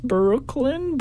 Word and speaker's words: Breukelen: So, I don't know if that's a play on words on Brooklyn, but it's spelled B-R-E-U-K-E-L-E-N. And it's kind of pronounced Brooklyn, but it's not really Brooklyn, like New York.